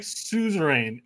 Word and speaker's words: suzerain: It's like suzerain. S-U-Z. It's one of their fancy words. R-A-I-N. Suzerain.